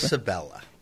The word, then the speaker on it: Quercibella: Well, how do you say the name of this? Carcibella. – Carcibella, and it's spelled how? – Q-U-E-R-C-I-B-E-L-L-A. – Carcibella. Yeah.